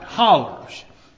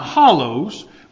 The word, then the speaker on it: hollers: And we call them, I don't know why we don't say hills, we say hollers. And I pronounce that right, hollers. Not hollows, which you'd probably say, oh, rednecks, is hollers.